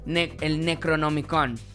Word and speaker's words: Necronomicon: Ya con esto, pues sí sabemos que es el Necronomicon, ¿verdad? Así se pronuncia.